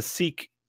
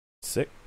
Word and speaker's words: sic: Why did he put a seek there? – Sick? Yeah, I don't know. – Seek. It's Latin, motherfucker. They pronounce the I like an E. – No, it's sick. S-I-C. – It is seek. It is Latin. – S-I-K.